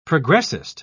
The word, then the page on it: progressist: British English